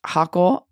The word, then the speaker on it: Hochul: I didn't think it was true at first, but Governor Kathy, I believe it's pronounced Hochul of New York,